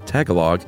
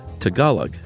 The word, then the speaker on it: Tagalog: Chuck said Tagalog. It's actually Tagalog.